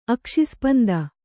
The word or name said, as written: ಅಕ್ಷಿಸ್ಪಂದ